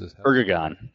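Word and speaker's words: Erdoğan: Erdogan, I think it's Erdogan.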